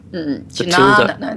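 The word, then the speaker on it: jñāna: J-N-A with a little squiggle on top, N-A. – Jnanya, then. – Jnana, it's a long A. – Oh, it's a long A, not a tilde over the N.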